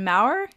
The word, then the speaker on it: Maurer: I'm your host, and today we have a special guest on the podcast, John Maurer. Is that how you say your last name? I should have confirmed that before. – That is.